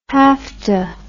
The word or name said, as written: hafta